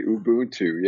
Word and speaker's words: Ubuntu: And you did pronounce it correctly, Ubuntu.